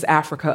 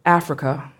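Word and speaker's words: Afrika: My name is Africa Afeni. Africa, spelled with a K, and Afeni after Afeni Shakur, member of the Black Panther Party and mother of Tupac Shakur.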